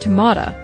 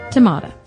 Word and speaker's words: tomato: But what you didn't say was whether it's pronounced tomata, like my mother says it, because that's a Virginia pronunciation.